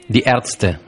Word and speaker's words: Die Ärzte: son Die Ärzte. – Die Ärzte, los médicos.